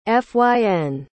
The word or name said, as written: fyn